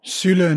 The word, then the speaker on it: Süle: The most recent are the Hoffenheim duo of Niklas Süle and Sebastian Rudi. Did I pronounce those right? – Süle and Rudi, yeah.